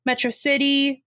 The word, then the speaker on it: Metro City: Megamind's pronunciation of Metro City was the same stress pattern as Metropolis. So Metrocity, Metropolis.